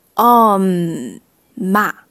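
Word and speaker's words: 엄마: 我们刚刚说的妈妈，他们是 oma，它就是第一个字就是带这个收音的 om ma oma oma。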